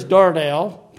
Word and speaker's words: darnel: Well, actually, I think it's pronounced the emphasis on the nail, but I can't say it. So in any case, the word is darnel, pronounced however you want to, translated tares.